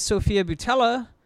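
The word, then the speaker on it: Sofia Boutella: Speaking of Delphine, that was Sophia Boutella of The Mummy.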